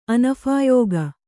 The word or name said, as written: ಅನಫಾಯೋಗ